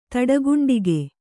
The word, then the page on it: ತಡಗುಂಡಿಗೆ: kannada